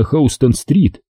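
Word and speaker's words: Houston: Миновали здание ООН и в конце концов очутились на Хаустон-стрит. Кстати сказать, пишется ее название так же, как имя техасского городка Хьюстона, но произносится по-другому.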